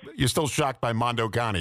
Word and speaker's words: Mondo Cane: And if you look up Mondo, C-A-N-E, Mondo Cane, Mondo Cani is the way it's pronounced, you'll see it.